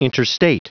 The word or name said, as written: interstate